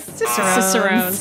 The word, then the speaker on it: Cicerones: I'm gonna pronounce it how I did earlier. Chicharrones. – Chicharrones, also known as Cicerones. – Cicerones this week.